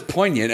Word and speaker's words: poignant: poignant poignant po- poignant poignant poignant poignant I thought it was poignant, and then I feel like I heard someone recently do a hard G, and I'm like, has my whole life been a lie? No, I'm fairly certain it's poignant. Poignant, it's got to be right.